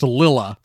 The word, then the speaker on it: Lila: Spelled Lila. Spelled L-I-L-A, but pronounced Lilla.